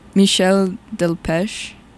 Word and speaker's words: Michel Delpech: Michel Delpech.